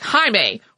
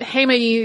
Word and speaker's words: Heimaey: Heads up that every piece of non-Icelandic video footage I have seen about this eruption pronounces it in some other way, like Heimaey or Heimaey or Heimaey, but Heimaey is closest to how I've heard people actually pronouncing it while I was in Iceland.